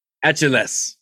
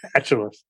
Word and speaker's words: Achilles: That guy in the Wheel of Fortune who couldn't pronounce Achilles was the was the most famous one. So, uh, what do you say? Mythological hero Achilles. Atulus.